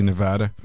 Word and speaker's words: Nevada: So if you're a soccer enthusiast here in Nevada, am I saying it correctly, Nevada? – Yeah.